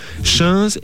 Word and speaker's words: chainse: Alors les tuniques, chins et bliots. Chins, ça s'écrit C-H-A-I-N-S-E et bliots, B-L-I-A-U-D. Depuis le Xe siècle, les deux pièces principales de l'habillement des hommes et des femmes sont le chinze et le bliot.